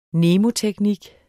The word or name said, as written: mnemoteknik